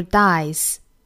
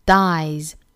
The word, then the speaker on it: dies: 我们来看最后一个单词 die，加上 s 的发音呢是 dies，它的尾音呢是要浊化成 z 的发音， dies 并不是 dies，应该是浊化的 z， dies 啊，因为 die 它本身是元音结尾，加上清辅音的话呢它要有所浊化，清清浊浊的语音规律哈， dies。